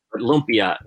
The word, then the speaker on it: lumpia: Lumpia is how you say it. L-U-M-P-I-A. Lumpia.